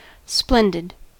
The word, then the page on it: splendid: American English